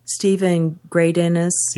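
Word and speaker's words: Steven Greydanus: I don't know if you saw the long piece that Stephen Gray-Dennis, that's the right way to say his name, wrote about Catholics interacting on the internet.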